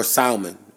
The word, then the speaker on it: salmon: There's no L in that shit, no. – Salmon. – Don't ever pronounce the L. But, it's no L in it? – No L. Salmon. – Oh, it's a silent L.